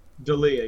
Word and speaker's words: D'Elia: How do you say his name? Is it D'Elia? – D'Elia, yeah.